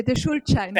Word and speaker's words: Schuldschein: Scooter Shine. I'm probably getting worse on my pronunciation there. So interesting to see. – The Schuldschein. – Thanks. That's what I was trying to say.